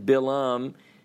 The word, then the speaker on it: Balaam: Okay, so last week we talked about this seer by the name of Balaam, B-A-L-A-A-M in English, pronounced Balaam in Hebrew.